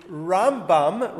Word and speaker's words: Rambam: Um, but they did, uh, but their names are similar in order to differentiate, the way Jews have traditionally done it is we call the Rambam, Rabbi Moshe ben Maimon, Maimonides, we put the stress on the beginning of the word.